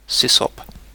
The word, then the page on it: sysop: British English